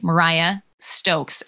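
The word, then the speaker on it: Mariya Stokes: But in case, it's M-A-R-I-Y-A, Mariah Stokes, S-T-O-K-E-S.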